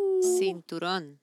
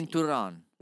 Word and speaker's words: cinturón: Cinturón. – Cinturón. – Cinturón. – How do you spell that? – C, as in Carlos. – Yeah. – I-N-T, as in Thomas. U-R-O-N, as in Nancy. – Cinturón.